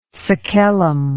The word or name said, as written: sacellum